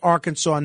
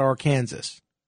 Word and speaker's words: Arkansas: Or if I do that, do I become the kid in class that says, oh, teacher, you know, it's pronounced Arkansas, not Arkansas.